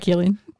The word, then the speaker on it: Cillian: And also, sorry, we've just been talking about this offline, that we've both been living our lives pronouncing it Cillian Murphy, not Cillian Murphy. – Sorry, Cillian. – I was today years old when I found out it's Cillian.